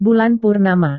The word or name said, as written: bulan purnama